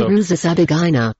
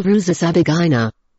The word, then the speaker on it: Rusesabagina: The movie stars Don Cheadle that plays Paul Rusesabagina.